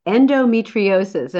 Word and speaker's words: endometriosis: Now, you do focus a lot on endometriosis.